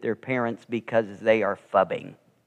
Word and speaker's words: phubbing: I don't even know if I pronounce it right. Fubbing? Fubbing? P-H-U-B-B-I-N-G.